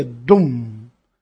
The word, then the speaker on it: Dum: Sua sílaba semente é Dum, D-U-M, Dum.